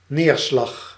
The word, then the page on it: precipitation: /prɪ.sɪ.pɪˈteɪ.ʃən/